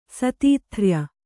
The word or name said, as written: ಸತೀಥ್ರ್ಯ